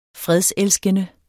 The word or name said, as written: fredselskende